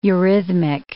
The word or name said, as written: eurythmic